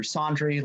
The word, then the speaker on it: Sondry: That's at Andrew Sondry. Last name is spelled basically laundry, but replace the L with an S.